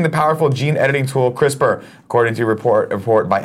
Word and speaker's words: CRISPR: CRISPR.